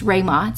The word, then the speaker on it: Ruima: It's Rayma, spelled R U I M A.